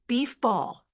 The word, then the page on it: beef ball: American English